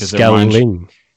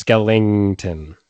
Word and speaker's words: Skellington: Listen, I love me a good Skelly, but I'm saying his name right. It's Skellington. Okay, interesting.